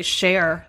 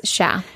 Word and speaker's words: cher: characters overuse the word "cha," which is spelled C-H-E-R. – And it's mispronounced. They say "share." – Yeah, sometimes. – They always say "share." – Hey, share. – But no, it's "cha." That's how you say it.